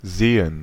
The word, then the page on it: Seen: /ˈzeː.ən/